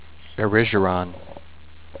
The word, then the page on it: Erigeron: err-RIDGE-err-on